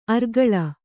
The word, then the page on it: ಅರ್ಗಳ: argaḷa